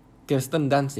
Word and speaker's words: Kirsten Dunst: Yang salah satunya, yang si ceweknya itu diperankan oleh Kirsten Dunst. Kirsten Dunst ya, bacanya. Iya, Kirsten Dunst pada saat muda.